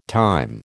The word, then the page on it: time: American English